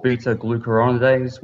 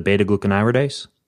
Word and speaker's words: beta-glucuronidase: So looking at their gut health, if you've got there's an enzyme called beta-glucuronidase, which will convert the estrogen that your body's trying to detoxify into estradiol in the gut.